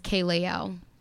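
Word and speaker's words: Kaelael: Hi, my name is Kaylana, but my artist name is Kaylayel.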